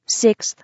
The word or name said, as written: 6th